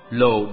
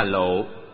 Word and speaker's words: lộ: Cam là ngọt, lồ đọc đúng là lộ, nghĩa là sương.